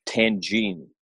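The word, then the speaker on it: tagine: And essentially what your diet consists of is a place called Tangine. – Yeah. – I think it's, is that right? Am I saying that right? – Yeah.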